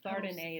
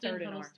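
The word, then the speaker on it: Thénardier: It's Thardin A. It's not Third and R.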